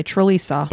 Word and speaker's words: Trlica: It's spelled T-R-L-I-C-A. – Okay, Trelisa.